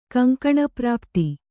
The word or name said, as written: ಕಂಕಣಪ್ರಾಪ್ತಿ